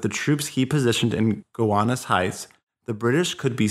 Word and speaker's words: Gowanus: Washington also sends 3,000 men to a wooded, hilly area called Gowanus Heights, which is really funny because it's spelled G-O-W-A-N-U-S, so Gowanus Heights.